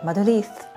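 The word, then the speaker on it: Madrid: この番組はマドリレーニョはマドリースと発音するマドリッドからジュリがお届けします。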